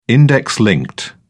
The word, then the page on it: index-linked: British English